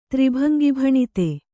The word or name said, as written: ತ್ರಿಭಂಗಿಭಣಿತೆ